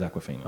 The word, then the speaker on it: Awkwafina: How do you pronounce it? – Aquafina. I thought it was Awkwafina. – It is Awkwafina. – Oh, yeah. Okay, Awkwafina.